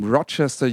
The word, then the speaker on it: Rochester: We're having Such Gold from, I don't know, from Rochester, USA. I'm not sure about the pronunciation of Rochester. – No, you nailed it. Yeah. Rochester, it's a city in Western New York.